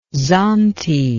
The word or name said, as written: zante